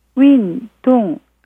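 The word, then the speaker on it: 윈, 동 (运动): 발음은 윈, 동. 입니다.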